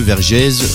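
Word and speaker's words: Vergèze: Ça s'écrit V-E-R-G-E-Z-E, Vergès, France.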